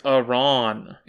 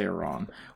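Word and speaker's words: Arawn: It's A-R-A-W-N. – I have such a powerful memory of it being Aron. I'm looking at the pronunciation guide. – I think you'll find that it literally says Aron.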